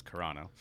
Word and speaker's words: Carano: It's not Carino, it's Carano.